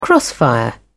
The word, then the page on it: crossfire: British English